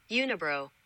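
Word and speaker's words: Unibroue: Unibro.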